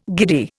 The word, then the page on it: giddy: Indian